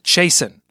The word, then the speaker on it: Chasson: First name is spelled Chasson, and it's pronounced Chasen, like Jason, but Chase, like don't go chasing waterfalls.